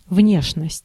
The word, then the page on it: внешность: /ˈvnʲeʂnəsʲtʲ/